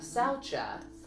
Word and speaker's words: saucha: saucha. I may be butchering it. Saucha. – Nope, that's how I heard it pronounced.